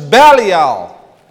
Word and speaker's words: Belial: Here Paul mentions Belial. And this is literally how it is pronounced.